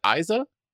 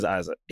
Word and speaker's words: Eiza: How do you pronounce Gonzalez's first name? Is it Isa? – I said I wasn't looking up nothing. I ain't got no IMDb's open or nothing. – E-I-Z-A.